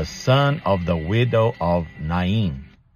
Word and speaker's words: Nain: Or Nain, as many people in English pronounce it. For those of us who speak Portuguese, to say Nain sounds strange. But I checked, and in Hebrew, to the best of my knowledge, they pronounce it Na-in.